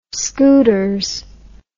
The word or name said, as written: scooters